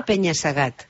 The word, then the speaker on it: penya-segat: No, se pronuncia penya-segat.